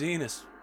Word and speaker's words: Enos: My name's Enos. Rhymes with penis.